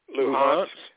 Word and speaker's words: Luhansk: Luhansk.